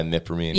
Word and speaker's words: imipramine: They prescribed him a medicine called imipramine. Am I saying that right? – Yeah, imipramine.